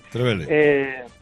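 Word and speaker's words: Trevélez: Bueno, pues resulta que cuando volvemos de Trévele, o Trévele, no sé cómo se pronuncia en realidad el pueblo, sí. – Trévele o Trévele. – Trévele.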